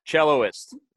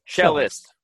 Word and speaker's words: cellist: Celloist. Is it celloist or like is that cellist? – Cellist. – Cellist. – Cellist is the proper pronunciation.